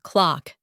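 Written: clock

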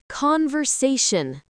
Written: conversation

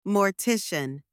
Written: mortician

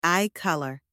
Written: eye colour

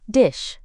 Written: dish

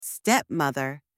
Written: stepmother

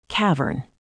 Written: cavern

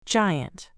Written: giant

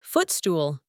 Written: footstool

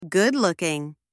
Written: good-looking

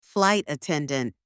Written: flight attendant

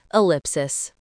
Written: ellipsis

